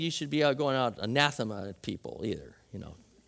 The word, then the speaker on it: anathema: But let's all say anathema generally out loud so you know how to say it.